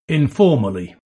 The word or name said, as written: informally